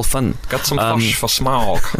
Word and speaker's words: Smaug: So apparently Peter Jackson pronounced it Schmaug. Schmaug. But he's got a New, New Zealand tint.